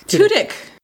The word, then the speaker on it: Tudyk: And then last but not least, we have Alan okay, how do you pronounce his last name? Is it Tudyk or Tudyk? – Tudyk or Tudyk? – Tudyk. – Every time I say his name, I always feel like I'm saying it wrong.